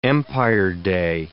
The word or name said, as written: Empire Day